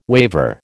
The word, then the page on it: waver: American English